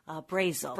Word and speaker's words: Brasil: Brazel.